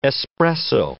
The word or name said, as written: espresso